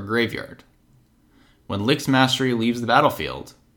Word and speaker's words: Lich's Mastery: Oh, I'm sorry. I was saying it wrong. Lich's Mastery.